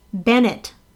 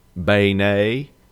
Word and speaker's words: beignet: How do you properly pronounce B-E-I-G-N-E-T? All right, New Orleans. All right. – Yep. – A, Bennett. B, Beignet. – Beignet. – And C, Beignet. – It's obviously Beignet. – It's C, yes, Beignet.